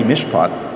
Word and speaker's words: mishpat: And they talk about, in the Old Testament, the word that is commonly translated justice is spelled M-I-S-H-P-H-A, mishpat.